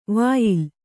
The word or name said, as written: ವ್ಯಾಲ